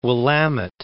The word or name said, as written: Willamette